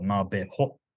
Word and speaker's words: Navajo: And like the way I spell the H, for example, like the Navajo is spelled with the H. I try to keep it as phonetic as possible. – Mm-hmm. – Navajo or Nabeho, as we pronounce it, Navajo.